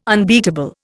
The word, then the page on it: unbeatable: Indian